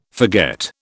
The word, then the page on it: forget: British English